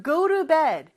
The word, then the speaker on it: go to bed: 자, 그래서 go to bed. 발음은 T 발음이 real처럼 부드럽게 하는 발음이에요.